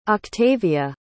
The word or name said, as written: octavia